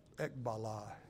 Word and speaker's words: ekballo: He uses a word called ekbala, and that is spelled E-K-B-A-L-L-O.